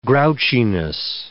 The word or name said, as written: grouchiness